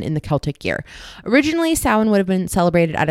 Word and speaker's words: Samhain: So that's S A M H A I N. It is pronounced Samhain. That rhymes with Rose McGowan.